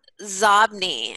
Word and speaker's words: Xobni: Or there's a company called Zobni, and it's spelled X-O-B-N-I, and that's inbox spelled backwards.